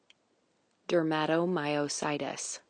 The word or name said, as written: dermatomyositis